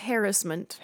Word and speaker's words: harassment: Also, it's pronounced harassment. – Harassment. – Thank you.